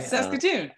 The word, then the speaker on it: Saskatoon: Well, I remember, Stephen, you talking about the last time you came, talking about the that pie that you like, the scatacoon or... – Scatacoon, actually. – Yeah. – Scatacoon, but it's close. – What is syllables? Saskatoonberry pie. – Saskatoon. I was close.